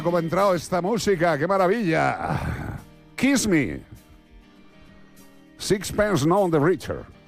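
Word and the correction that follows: Sixpence None the Richer: Sixpence, none the richer.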